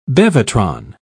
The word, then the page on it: bevatron: British English